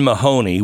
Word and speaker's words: Mahoney: The San Francisco State University president, Lynn Mahoney, which rhymes with baloney, wrote a letter to the campus community And in it, she called the Riley Gaines incident deeply traumatic for trans people.